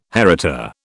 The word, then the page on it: heritor: British English